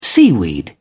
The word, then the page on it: seaweed: British English